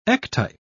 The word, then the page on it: ectype: British English